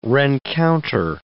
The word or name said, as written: rencounter